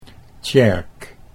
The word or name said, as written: Tjerk